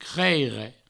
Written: creire

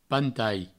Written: pantai

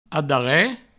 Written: adarre

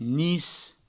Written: nis

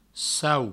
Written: sau

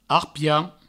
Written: arpien

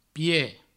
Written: pied

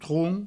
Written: tron

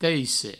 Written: teisse

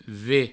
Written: ve

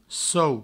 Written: sou

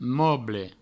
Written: moble

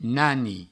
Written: nani

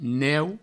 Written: neu

